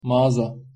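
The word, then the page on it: magaza: Turkish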